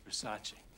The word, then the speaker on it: Versace: It's Versace. It's Versace. What? Versace. It's pronounced Versace.